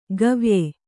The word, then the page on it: ಗವ್ಯೆ: gavye